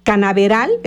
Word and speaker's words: Canaveral: Canaveral, es que no se escribe la ñ, por eso es Canaveral 100.5 MX, transmitiendo totalmente en vivo desde calle Miguel Hidalgo 109 aquí en Ocotepec, precioso poblado de Ocotepec.